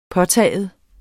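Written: påtagen

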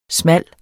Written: smal

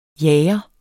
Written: jager

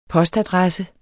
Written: postadresse

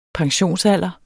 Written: pensionsalder

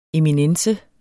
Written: eminence

